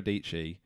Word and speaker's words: Dietschy: And Sarah Dietschy rhymes with Peachy.